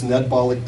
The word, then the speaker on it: Ned Bollock: My name is a little hard to pronounce because it's, come in, please. It's a little unfamiliar, but it's Ned Bollock pronounced like Carbolic.